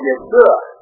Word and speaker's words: Yeshua: There's no J in Hebrew. No J in the pronunciation. So he was called Yeshua.